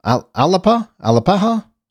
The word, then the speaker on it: Alapaha: The largest wild hog found ever was discovered and found and killed in Alapa. Am I saying that right? In Alapa, Georgia. Alapaha, Georgia.